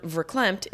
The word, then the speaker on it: verklempt: By the way, the Yiddish word verklempt is pronounced with an F.